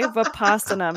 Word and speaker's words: Vipassana: I will name that the narrator mispronounces Vipassana consistently through the entire book, which kind of drove me nuts. Is that what he was saying? – That's what he was trying to say. Vipassana meditation, which I've done multiple times. So I'm like, ooh, nails on a chalkboard. You're saying it wrong.